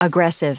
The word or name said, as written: aggressive